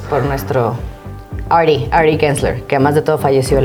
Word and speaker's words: Gensler: Es, Gensler.